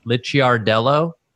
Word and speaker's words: Licciardello: Licciardello. Carman Licciardello. Is that how you would say that in Italian with the two C's? – Yes.